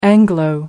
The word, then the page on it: anglo: British English